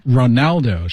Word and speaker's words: Ronaldo: Ronaldo, 是 R-O-N-A-L-D-O, Ronaldo, Cristiano Ronaldo。